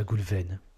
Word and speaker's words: Goulwenn: Tu vas nous raconter ça, Goulven. J'accueille donc euh, Goulven Tristan. Est-ce que je prononce bien ton nom déjà, Goulven Ouais, très bien. Avec le, prénom, c'est Goulven et le nom, c'est Tristan.